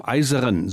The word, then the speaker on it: IJzeren: Nur gehen noch kurz über die Grenze zu dem Ortsnamen Eiseren. So wird er wohl ausgesprochen. Schreibt sich folgendermaßen I-J-Z-E-R-E-N.